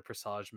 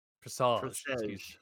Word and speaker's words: Presage: Like, obviously, I adore talking about it, but what Destiny has brought to the table with the Presage mission is something else entirely. – Sorry, you mispronounced it. – Presage.